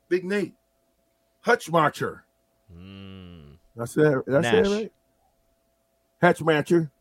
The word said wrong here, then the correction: Hutmaker: No, Nash Hutmaker. Technically, that's actually how you say it. We asked his parents. Nash. We saw them at Tipsy Tina's last year for a pregame. It's actually pronounced Hutmaker.